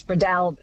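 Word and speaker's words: Breadalbane: David in Tamworth says, I always understood it to be Bredolban with the emphasis on the second syllable, the third almost not pronounced, which is such an Aussie way of doing it. Bredolban, do you think, does that sound good? – Close, more A on the A.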